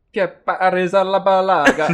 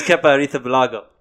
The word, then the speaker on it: Kepa Arrizabalaga: Kepa Arrizabalaga. – It's Kepa Arrizabalaga.